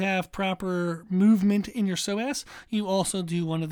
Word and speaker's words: psoas: Now, for the core, we do want to pay attention to a muscle called the psoas, which is P-S-O-A-S. So it starts with a P, even though it's pronounced psoas. So there's no P there in the sound of it.